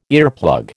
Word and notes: earplug: American English

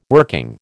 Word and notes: working: American English

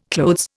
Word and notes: clothes: Indian

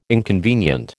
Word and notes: inconvenient: American English

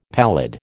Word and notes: pallid: American English

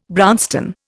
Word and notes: brownstone: Indian

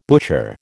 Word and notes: butcher: American English

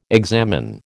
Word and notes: examine: American English

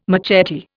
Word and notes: machete: Indian

